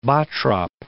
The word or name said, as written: Bottrop